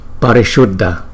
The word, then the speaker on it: Parishuddha: Parishuddha, Parishuddha, Parishuddha, Parishuddha. Chữ S này cong lưỡi lên, Parishuddha, Parishuddha. Dhammate, Dhammate, Dhammate, Dhammate.